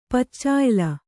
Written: ಪಚ್ಚಾಯ್ಲ

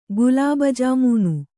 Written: ಗುಲಾಬಜಾಮೂನು

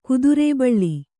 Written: ಕುದುರೇಬಳ್ಳಿ

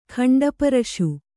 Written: ಖಂಡಪ್ರಾಸ

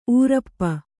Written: ಊರಪ್ಪ

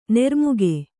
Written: ನಿರ್ಮೇಘ

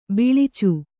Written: ಬಿಳಿಚ

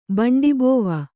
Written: ಬಂಡಿಬೋವ

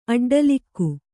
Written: ಅಡ್ಡಲಾಗಿ

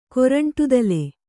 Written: ಕೊರಂಟುದಲೆ

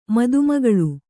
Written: ಮದುಮಗಳು

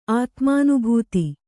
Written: ಆತ್ಮಾನುಭೂತ

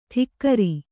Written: ಠಿಕ್ಕರಿ

